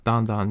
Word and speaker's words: Dan Dan: I think it's pronounced Don Don noodles.